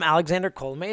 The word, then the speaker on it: Colomé: And the reason I call him Alexander Colomé is because in that video, he told me how to say his name.